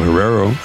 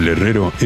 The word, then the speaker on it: Herrero: I'm Rafael Herrero, Rafael Herrero, if you can roll your R's.